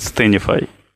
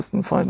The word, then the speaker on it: Stanfy: Нет, не одному, а, но обычно произносят Стэнфи. Вот, а, потому что Стэнфордский университет часто люди называют Стэнфи. Но так пошло, что название компании а, учредители когда называли, но корень был от Stand and Fight. Невзирая на его написание, оно называется Stand Fight. Произносится Стэнфай, да.